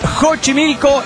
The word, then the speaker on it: Xochimilco: Hemos aprendido que se dice Xochimilco y México. Porque la X depende de la situación.